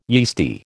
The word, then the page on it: yeasty: American English